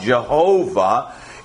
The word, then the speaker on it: Jehovah: Jehovah is how we pronounce it in English.